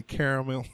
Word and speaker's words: caramel: And I was getting ready to say caramel. What is the correct pronunciation of that word? – You know what, caramel.